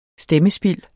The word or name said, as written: stemmespild